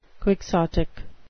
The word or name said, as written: quixotic